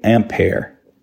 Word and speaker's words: Ampère: André Marie Ampère. I mispronounced the one I did in the mission too. The amp, you know, like the amp, plug your guitar in, the base unit of electrical current.